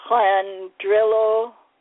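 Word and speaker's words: Llandrillo: L-L-A-N-D-R-I-L... – L-A-N is like Flan. – Clandrillo, Merionethshire.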